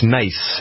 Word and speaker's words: Nice: It's nice. It's nice in southern France. – A very popular tourist destination this time of year, too. – Yeah, it's spelled nice, but it's pronounced Nice.